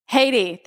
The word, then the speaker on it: Haiti: How do you guys say it? – Haiti? – Tell her. – Haiti, thank you.